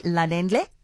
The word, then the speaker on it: Lalendle: We say welcome to Freddie Lalendle.